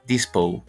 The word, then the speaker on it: Dispo: Dispo. – And it rhymes with disco in the song, which I really like.